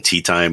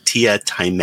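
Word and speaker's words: Teatime: And there's a character in there called Tia Time, but it's spelled Tea Time. So people keep calling him Tea Time. And he's like, no, it's Tia Time.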